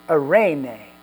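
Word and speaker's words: eirene (εἰρήνη): And in the Greek, this word peace is pronounced eirene, eirene, and it means a quietness of soul.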